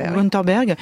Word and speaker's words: Wunderberg: Voilà, donc il s'appelait Chris Wunderberg, c'est comme ça qu'on le prononce ? – Wunderberg.